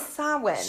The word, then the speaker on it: Samhain: It is spelled Samhain. – Yes. – So, and some people do pronounce it that way. Okay. – But that's not correct. It is Samhain.